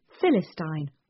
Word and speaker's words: Philistine: Quadrille was to be pronounced with the accent on its last syllable, Philistine on its first.